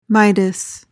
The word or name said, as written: midas